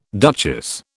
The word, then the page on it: duchess: British English